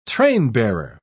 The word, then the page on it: trainbearer: British English